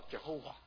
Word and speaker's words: Jehovah: 所以现在弟兄姊妹要弄清楚，耶和华不是神的真名啊，是犹太人不敢妄称神的名字，不敢称亚威，所以呢把 Adonai 主这个字的母音放在亚威这个字的下面，就产生一个新的读音叫 Jehovah。